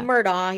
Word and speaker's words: Murdaugh: Murdaugh, yeah.